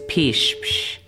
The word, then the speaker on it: pishpsh: The Tsklallam word of the week is pishpsh, which means cat. This word is used to practice the letter P in the Tsklallam alphabet. The P sound in Tsklallam is pronounced just like the English letter P.